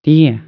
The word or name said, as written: tejer